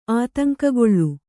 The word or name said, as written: ಆತಂಕಗೊಳ್ಳು